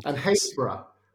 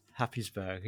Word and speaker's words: Happisburgh: H-A-P-P-I-S-B-U-R-G-H. – Happysborough. – You're Happysborough. Yeah, but it's pronounced Haysborough.